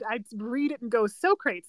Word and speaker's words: Socrates: Man, I took philosophy in philosophy 101 in university, just for like a fun course, and it took everything in me to remember to pronounce Socrates' name as Socrates. – Yeah, you have to say it. Say it in your head Socrates.